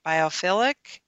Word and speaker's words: biophilic: What is, and maybe I'm not saying it right, biophilic design? – Yeah.